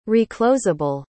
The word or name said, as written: reclosable